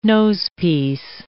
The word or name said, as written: nosepiece